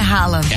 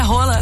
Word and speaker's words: Haaland: E se eu contar pra vocês que a pronúncia do Haaland não é Haaland? – É rala. – Não, é rola.